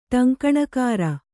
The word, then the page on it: ಟಂಕಣಕಾರ: ṭaŋkaṇakāra